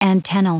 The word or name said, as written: antennal